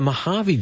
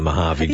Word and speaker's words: Mojave: Mojave is spelled M-O-H-A-V-E. – Correct. – But the Mojave Desert, I think, is a J, a silent J, as in June and July.